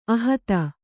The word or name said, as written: ಅಹಿತೆ